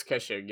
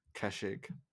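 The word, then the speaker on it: Kheshig: Yes, and so we have the Khan's bodyguard, the Kenshig. Is that how you pronounce it? – It says Kenshig. Yeah. – Kenshig, who have very cool models, by the way, very cool models.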